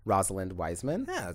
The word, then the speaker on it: Rosalind Wiseman: And it was based on a nonfiction self-help book called Queen Bees and Wannabes by Rosalind Wiseman. – Yeah, correct. That's the correct way of pronouncing it.